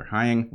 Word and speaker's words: Heying: This is Dr. Heather Hying, rhymes with flying.